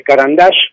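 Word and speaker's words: Caran d'Ache: Doré se basó muchísimo, incluso hay, viste los lápices, Carandash. – Sí. – Carandash es un, un seudónimo de un francés que nació en Rusia, porque parece que en ruso lápiz se dice Carandash, o se pronuncia fonéticamente así,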